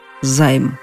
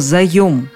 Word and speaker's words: заём: Но куда потратят этот займ? Да, так иногда говорят «займ», не подозревая о том, что такого слова нет. Есть слово «заем», в таком виде оно находится в толковом словаре «даля» – «заем» от слова «занимать», «взятие в долг» с обязательством «возвратить».